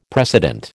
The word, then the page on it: precedent: American English